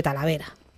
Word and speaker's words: Talavera: Talavera.